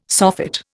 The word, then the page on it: soffit: Indian